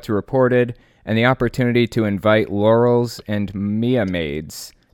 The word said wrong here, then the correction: Mia Maids: to serve as Maya maids. – Maya maids, okay.